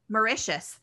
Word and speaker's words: Mauritius: And one of the ideas is at the African Leadership University in Rwanda and Mauritius. Sorry if I've mispronounced that. They declare missions. – Mauritius. – Thank you.